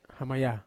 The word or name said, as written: hamaya